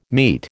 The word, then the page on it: meat: American English